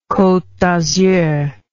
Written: cote d azur

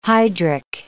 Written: hydric

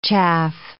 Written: chaff

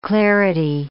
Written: clarity

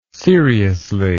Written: seriously

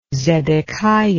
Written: zedekiah

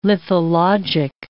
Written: lithologic

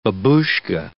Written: babushka

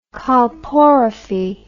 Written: colporrhaphy

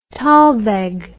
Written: talweg

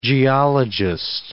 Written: geologist

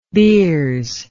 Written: beers

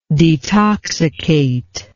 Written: detoxicator

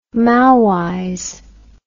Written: Maoize

